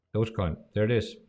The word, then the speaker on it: Dogecoin: Wasn't it also, what's his name, Mark Cuban, who accepts Dogecoin? – Yeah.